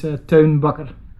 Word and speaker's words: Teun Bakker: Yeah, my name is Teun Bakker. Teun being my first name, and it's a typical Dutch name.